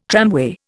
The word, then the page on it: tramway: Indian